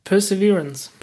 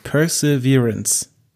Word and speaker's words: Perseverance: Perseverance. – Perseverance.